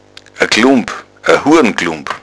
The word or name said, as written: glump